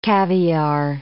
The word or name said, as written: caviare